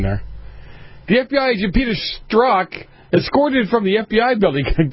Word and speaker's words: Strzok: Peter Strzok was escorted from the well, come on, you say that.